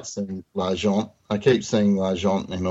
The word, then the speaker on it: L'Argent: We need to pronounce our R's. So L'Argent, like Steve L'Argent.